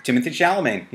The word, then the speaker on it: Timothée Chalamet: So Call Me by Your Name um, has little Timothée Chalamet. Did I say his name right for the first time? – Timothée Chalamet. Yep.